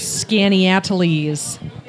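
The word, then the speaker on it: Skaneateles: 11 Finger Lakes, Canadice, Canandaigua, Cayuga, Canisius, Hemlock, Honeyoy, Cayuga, Otisco, Owasco, Seneca, and of course the cleanest and most beautiful, Skinny Atlas. – That is not spelled how it's pronounced. – No, it's Skinny Atlas.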